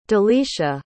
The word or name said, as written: delisha